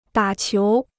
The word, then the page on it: 打球: dǎ qiú